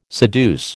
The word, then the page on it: seduce: American English